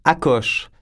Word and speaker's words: Akos: Akos, ça s'écrit A-K-O-S, qui fait en sorte que je me fais appeler régulièrement Akos. – Bon. – Là-dessus, les gens, je les corrige.